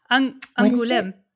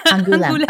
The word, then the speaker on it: Angoulême: And I saw so many memes coming out of France because Aglumé, forgive me for the pronunciation. – What are you calling like, it? Angoulême. – Angoulême. Okay, thank you.